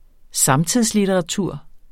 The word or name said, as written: samtidslitteratur